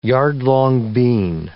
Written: yard-long bean

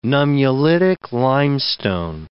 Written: nummulitic limestone

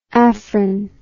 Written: afrin

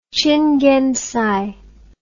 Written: qinggengcai

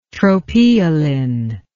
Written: tropeolin

